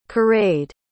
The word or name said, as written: caraid